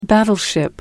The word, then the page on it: battleship: American English